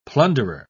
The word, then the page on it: plunderer: British English